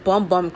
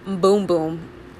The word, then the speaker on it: Bum Bum: I think it was Instagram or Twitter where the brand said that the cream is pronounced Boom Boom. Not Bum Bum. I've always said Bum Bum Cream. It's called Boom Boom Cream.